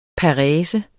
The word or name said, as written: parese